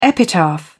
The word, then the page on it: epitaph: British English